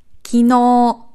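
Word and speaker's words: Kino (昨日): Eins von meinen Lieblingswörtern, weil es so oft falsch ausgesprochen wird. Kino. Kino. Gestern. Ist nicht Kino. Ja? Wir sind im Deutschen sehr oft verleitet, irgendwie die erste Silbe zu betonen, aber das ist eher selten der Fall. Kino.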